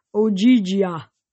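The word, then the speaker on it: Ogygia: As to where you are, you're in Ojigia. She pronounced it like Ojigia.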